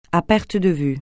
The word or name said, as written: à perte de vue